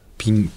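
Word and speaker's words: PING: ピン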